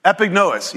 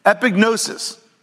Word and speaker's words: epignosis: Epignosis. Yeah, I said it right. Gnosis. I forgot the S. Epignosis. Epignosis is a Greek word that's a very specific type of knowledge.